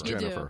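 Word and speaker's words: Gennifer: Please, it's spelled Jennifer because it's spelled with a G, but I thought you pronounced it Jennifer. – It is Jennifer. – Yeah. It should be spelled S-L-U-T.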